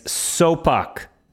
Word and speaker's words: Sopuck: You mispronounced his name. It's Sopuck.